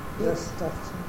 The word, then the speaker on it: Gustafsson: It's Swedish. Gustafsson. – Is it Gustafsson? – Yeah.